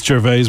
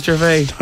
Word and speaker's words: Gervais: It's Gervais, by the way. – Isn't it Gervais? Don't hose it now. – Oh, I always thought it was Gervais. – No, it's not Gervais. – Hello, Ricky Gervais.